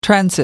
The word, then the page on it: transit: American English